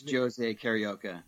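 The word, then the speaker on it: José Carioca: They're bringing in Panchito and Jose Carco. They are doing, or Carcoa, I forget how to say it. – Jose Carioca. – Yeah.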